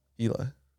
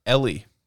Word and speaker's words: Eeli: How do you think you pronounce E-E-L-I? – Eli. – Ellie.